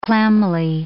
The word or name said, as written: clammily